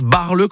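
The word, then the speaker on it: Baarle: Mais ça se prononce Barle comme ça, hein. Mais B-A-E-R-L-E.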